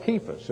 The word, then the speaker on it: Cephas: I am of Paul, and I of Apollos, and I of Cephas, as that should be pronounced with a hard K sound, and I of Christ.